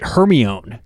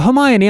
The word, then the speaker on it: Hermione: In elementary school, way back in the day, I read one of the Harry Potter books before they came out, and I pronounced the Hermione Granger, I pronounced it Hermione. – Yeah. – And so when I saw the movie, they go, Hermione. I'm like, Hermione? I was off this whole time.